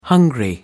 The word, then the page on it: hungry: American English